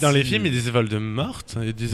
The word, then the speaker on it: Voldemort: De Voldemort. Eh, parce qu'on prononce pas le T parce que c'est Voldemort, comme, parce que c'est Voldemort littéralement en français.